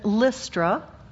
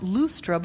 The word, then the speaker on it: Lystra: Paul meets him in Lystra, actually probably pronounced Lustra, but it sounds funny, on his second missionary journey.